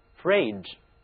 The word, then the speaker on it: fridge: 这个词中呢也有一个读音 G，不过它呢是由 D-G-E 这三个字母拼合一起读出来的， Fridge。